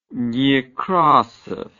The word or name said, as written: Nekrasov